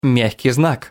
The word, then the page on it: ь: Russian